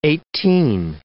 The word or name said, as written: eighteen